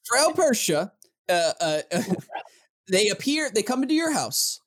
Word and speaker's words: Perchta: Bershta. Pershta. – Or it's a hard C. I don't know. – It's Frel Pershta. – Yeah. – P-E-R-C-H-T-A.